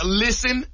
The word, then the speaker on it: listen: Listen is another one where the English words has a silent letter. – Silent and listen. – T. Listen. You ain't no T in listen.